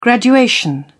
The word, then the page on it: graduation: British English